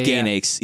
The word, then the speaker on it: Gainax: Gainax. – Left Gainax.